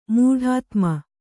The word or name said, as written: ಮೂಢಾತ್ಮ